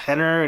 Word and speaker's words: Renner: It's spelled Renner, but it's pronounced Henner.